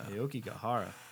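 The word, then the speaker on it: Aokigahara: Aoki Gahara. – That's correct. – This chair is squeaky. – That was a good pronunciation.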